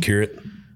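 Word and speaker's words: Przekurat: How do you say his last name? – Shakirit.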